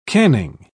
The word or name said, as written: canning